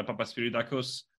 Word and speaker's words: Papaspyridakos: Uh, today we're traveling to Boston, United States, to meet um, Dr. Panos uh, Papaspyridakos.